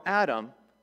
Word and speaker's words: Adam: Adam is how it's pronounced in Hebrew, and it means man, by the way. Very creative name. And so Adam, he was the one who God, you know, took from the dust to the ground, breathed the breath of life into him.